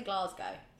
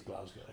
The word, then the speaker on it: Glasgow: I know, I always say it wrong. I say Glasgow. – It is Glasgow.